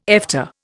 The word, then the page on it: Efta: Indian